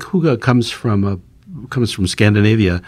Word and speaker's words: hygge: I had to ask her how to pronounce this word that looks like higgy. She helped me out. – So it's pronounced hygge. Hygge.